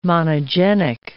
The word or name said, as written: monogenic